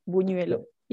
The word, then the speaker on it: buñuelo: It can be any food, but we mainly eat buñuelo. That is and so I'm glad custard. I know that word now. – Buñuelo, and you pronounce it really good, buñuelo.